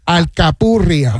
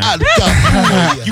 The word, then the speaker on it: alcapurria: Alcapurria. – I'm calling my wife that later tonight. – Alcapurria.